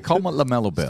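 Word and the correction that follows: LaMelo Ball: Still off season. – Ball. I got to say his name right first.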